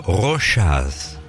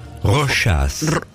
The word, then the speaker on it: Rochas: Mire, primero vamos a ver la R. – Ay, perdón. – Rochas. – Es que no conocía la marca, perdón. – Bueno, Rochas. Rochas.